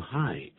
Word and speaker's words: Hi: Hey, 这是引起人注意的时候，通常不礼貌的时候，那已经顾不到礼貌的时候，就说这个 Hey 这个字。打招呼用 Hi, H-I, 可不要呢一看到 H-I 念成 He, 那不对了啊，念 I 的发音。